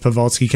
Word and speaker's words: Pavelski: Pavoski? – Yeah. – But now it looks like Joe Burns is going to be the statesman essentially at the top of the order with Pavelski coming in.